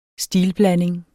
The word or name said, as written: stilblanding